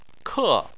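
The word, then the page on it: ke: tone 4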